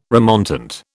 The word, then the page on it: remontant: British English